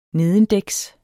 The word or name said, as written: nedendæks